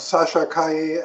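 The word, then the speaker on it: Sasha Calle: I actually like saying her name now that I know how to pronounce it properly. – Do you get annoyed though if you watch someone else and it's called like Calais or something? – I'm like, it's like, hello, it's she has, you know, Spanish or Peruvian or Colombian roots. Learn to pronounce it more.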